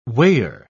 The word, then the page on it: weigher: British English